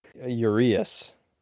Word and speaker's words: Urías: How do you say Urías? It's Urías? – Urías. There's an accent on the I, and then you said you didn't say Alcantara.